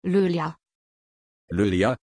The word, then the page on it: loélya: German